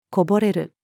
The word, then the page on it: 零れる: female